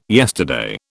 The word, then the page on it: yesterday: British English